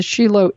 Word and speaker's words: Shiloh: which is Shiloh or Shilo. That's spelled S-H-I-L-O-H.